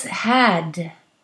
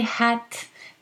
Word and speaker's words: had: Finally, I want to remember that the past of I have a brother, is had. And you should pronounce this D very clearly, okay? If you say hat, then it does sound like a hat that you put on your head.